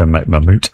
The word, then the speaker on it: Mammut: This is going to be, I think it's pronounced Mammut, M-A-M-M-U-T, and with a hyphen thing above the U, so Mammut.